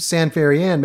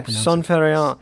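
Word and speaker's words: ça ne fait rien: Well, it's pronounced San Ferian, maybe San Ferian. – Yeah, San Ferian.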